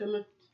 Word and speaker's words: Kemet: an ancient root in the Kemet? Is that, how do you pronounce it? – Yes, Kemet.